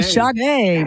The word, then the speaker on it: Sade: I called him Sade. I'm like, oh, I never lived that one down. So yeah, Sade.